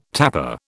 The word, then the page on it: tapper: British English